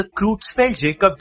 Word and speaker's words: Creutzfeldt-Jakob: Okay, how did you come up to that rare disease, Creutzfeldt-Jakob? – All right. So the exact pronunciation of this disease is the Creutzfeldt-Jakob disease, which I know is very difficult to pronounce at once.